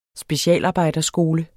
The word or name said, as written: specialarbejder